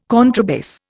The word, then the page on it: contrabass: Indian